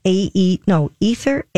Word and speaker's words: Aether: I think you'd pronounce it Arthur, but it's Ether, A-E-T-H-E-R, Foster Leather Jacket.